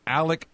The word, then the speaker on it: Alec: Defensive end Alec Bryant. And look, don't make the mistake. You pronounce it like a C, like Alec Baldwin, like Alec Box Stadium.